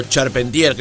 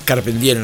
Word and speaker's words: Charpentier: Santiago Moreno Carpentier, o Charpentier, creo que es Carpentier en realidad la pronunciación de ese apellido,